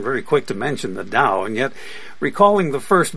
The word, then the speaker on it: Tao: The sense of Tao, D-T-A-O, sometimes D-A-O, it's pronounced as a D in any case,